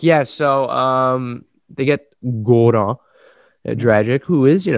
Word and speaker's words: Goran: And in the return, the Raptors get Doran Dragic. Am I saying that right? Dragic. – Goran, Gor- Goran, Dragic.